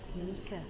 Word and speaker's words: Micah: M-I-C-A-H. – M-I-C-A-H? – Ready. – R-E-D-D-Y. It's not Misha, it's Mika. – Not Mika, he's pronounced it Mika. He says Mika.